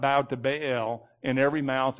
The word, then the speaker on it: Baal: In Hebrew, when you get two vowels, it's usually two sounds. You got to say them side by side. That's why it's Baal, not Baal, like most of us say. You got to pronounce both of them.